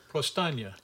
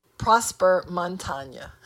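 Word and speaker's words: Prosper Montagné: Prosper Montagne. What is it? – Prostagna. – No. Prosper Montagne. I know, it's hard to say.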